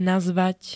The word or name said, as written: nazvať